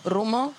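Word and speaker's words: Rummo: Rumo. – How do you spell that? – It's R-U-M-M-O.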